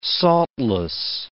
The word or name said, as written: saltless